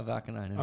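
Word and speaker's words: Vaakanainen: And then the third line, Vakanainen. – Okay. – Did I say his name right? – Yeah, Vakanainen.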